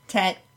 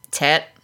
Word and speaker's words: tit: And how did you pronounce that? Was there an E in there? – Probably. – Tet. – Tet. Yep.